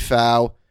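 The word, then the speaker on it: Pfau: And I guess she spoke to Wade Fow. Did I say that right? The P has to be silent, right? – Sounds right to me.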